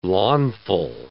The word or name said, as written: Launfal